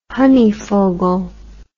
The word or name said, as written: honeyfogle